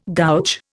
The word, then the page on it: gouge: Indian